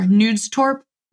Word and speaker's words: Knudstorp: kind of internally focused in Denmark and under Corey, I pronounced it incorrectly earlier. Knudstorp.